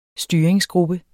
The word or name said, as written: styringsgruppe